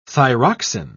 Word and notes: thyroxin: British English

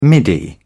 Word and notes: middy: British English

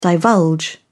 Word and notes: divulge: British English

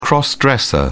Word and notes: cross-dresser: British English

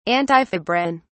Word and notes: antifebrin: British English

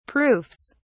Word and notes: proofs: British English

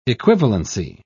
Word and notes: equivalency: British English

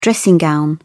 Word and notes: dressing gown: British English

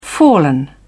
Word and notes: fallen: British English

